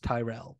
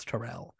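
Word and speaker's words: Tyrrell: Yeah, because I had been reading it in my head as Tyrell, but apparently it's Terrell.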